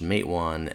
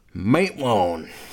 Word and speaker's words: Matewan: and apparently the town of Matawan is they pronounce it Matawan, but in the movie it's pronounced Matewan, and somebody said it's in a hillbilly voice, so Matewan.